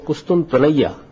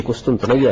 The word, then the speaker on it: قسطنطنیہ: آپ مجھے بتا رہے تھے کہ اس کا صحیح جو ہے تلفظ یہی ہے. – علامہ اقبال نے کہا نا یعنی وہ قسطنطنیہ, تو یہ قسطنطنیہ ہے اس کا صحیح.